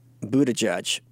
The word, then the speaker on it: Buttigieg: His name is Pete Buttigieg.